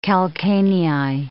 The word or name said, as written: calcanei